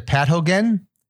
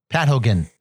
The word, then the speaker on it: pathogen: Again, you pronounce it as what? – Pat Hogan. – It's not a Pat Hogan. Pat Hogan is that's a fella. That's Hulk Hogan's younger brother. – Huh. – No, pathogen is it's a disease.